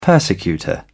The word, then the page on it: persecutor: British English